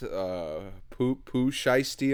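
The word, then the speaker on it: Pooh Shiesty: Poo Shiesty, am I saying that right? – Yeah.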